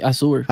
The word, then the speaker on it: Azure: Tienen la tecnología, tienen los chavos, tienen todo lo necesario para hacer, porque ellos tienen el, Azure, no recuerdo cómo se escribe, se pronuncia. Sí, Azure.